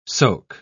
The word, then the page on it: soke: British English